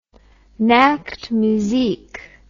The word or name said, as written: Nachtmusik